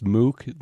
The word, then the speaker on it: MOOC: And there was an article that you have in there, is the term pronounced MOOC, the massive online MOOC? – Yes.